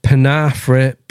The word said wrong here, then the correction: Penarth: Pier. Have I said that right? I think I said that right. I probably haven't. Penarth Pier. Penarth Pier in the valley of Glamorg.